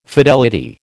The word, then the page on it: fidelity: American English